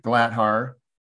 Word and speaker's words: Glatthaar: Richard Glatthar, R-I-C-H-A-R-D, last name G-L-A-T-T-H-A-A-R.